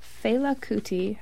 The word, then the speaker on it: Fela Kuti: Who is featured on the cover of albums by the Ramones, Fela Kuti, I don't know if I'm pronouncing that right. – That's right.